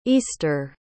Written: eastre

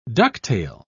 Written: ducktail

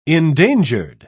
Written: endangered

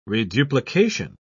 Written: reduplication

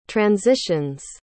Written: transitions

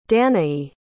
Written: danae

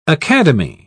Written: academy

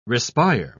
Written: respire

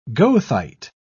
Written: goethite

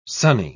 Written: sunny

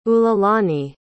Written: ululani

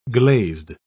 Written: glazed

